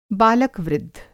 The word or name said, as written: बालक-वृद्ध